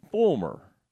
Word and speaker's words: Folmar: All right, Aaron, it's spelled F-O-L-M-A-R, but every time I listen to an interview, I hear Fulmer. So I'm kind of going to go with Ryan Fulmer.